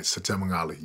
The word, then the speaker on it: Setema Gali: That's right, Setema Gali.